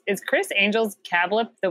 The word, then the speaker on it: CABLP: C-A-B-L-P. Kablip. – Cab-L-P. – Cab... More like C-A-blip. Kablip. – And did you look up the pronunciation? – I did. It says it right here. It's pronounced, in this article I'm reading, it says it's pronounced Kablip, but it's spelled, it's spelled Kab-L-P, but it's pronounced Kablip. I'm going to say it again.